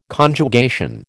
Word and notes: Conjugation: American English

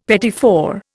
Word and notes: petit four: Indian

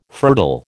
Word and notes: fertile: American English

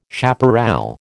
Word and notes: chaparral: American English

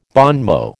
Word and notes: bon mot: American English

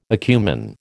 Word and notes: acumen: American English

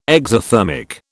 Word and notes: exothermic: British English

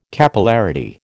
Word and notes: Capillarity: American English

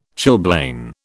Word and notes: chilblain: British English

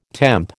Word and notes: temp: American English

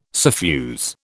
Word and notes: suffuse: British English

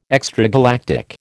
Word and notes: extragalactic: American English

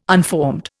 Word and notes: unformed: Indian

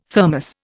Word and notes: Thermos: Indian

